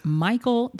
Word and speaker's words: Mychal: And so I'm happy to say I write, my major pen name is for science fiction, fantasy, paranormal, romance under Michael Daniels. And that's spelled M-Y-C-H-A-L Daniels.